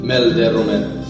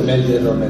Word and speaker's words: mel de romer: Ahora vosotros tenéis que pronunciar Mel de Romer. – Mel de Romer. – ¿Era tú? – Mel de Romer. – Muy bien, chiquets.